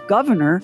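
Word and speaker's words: Gouverneur: The baby boy was christened Governor, pronounced like the elected office, but spelled like a fancy French cheese.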